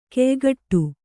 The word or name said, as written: ಖಯಖೋಡಿ